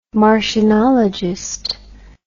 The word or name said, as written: martianologist